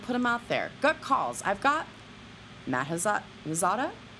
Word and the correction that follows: Asiata: Is that pronouncing it? Why not? – Asiata? – Yeah, that sounds better.